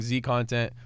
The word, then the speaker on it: zContent: But like I'm saying zContent, but the Z is silent, so whenever you meet me, just call me Content, because that's who I am.